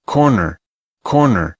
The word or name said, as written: corner